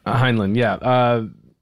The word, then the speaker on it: Heinlein: Heinlein.